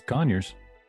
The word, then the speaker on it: Conyers: Did I say that right? Conyers, Georgia? – Yeah. Oh, it's Conyers. – Yeah, it's Conyers.